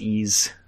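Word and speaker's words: Ys: Yeah, it's just the letter Y, the letter S. It's pronounced Ease.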